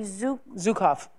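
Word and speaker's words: Zukav: I remember, Oprah, a show that you did with, and I hope I say his name right, Gary Zukov.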